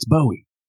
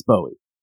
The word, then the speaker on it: Bowie: Learn how to pronounce Bowie, and I had to answer someone and say, "Look, I'm a Yank. It's Bowie. You can say Bowie all day long, uh, but it's Bowie."